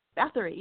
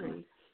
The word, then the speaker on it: Bathory: Am I saying that right? – Bathory? Duology. – Bathory, isn't it? – Bathory, yep. – Yeah. Bathory.